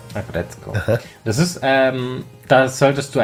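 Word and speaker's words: Aggretsuko: Das Ding ist auch auf Netflix, heißt Agretzko. Mhm. Geht gerade voll durch die Wand. Also das schreibt man Agretzuko, aber man spricht Agretzko aus. – Agretzko.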